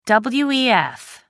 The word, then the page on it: wef: American English